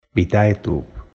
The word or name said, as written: VitaeTube